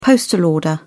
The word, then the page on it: postal order: British English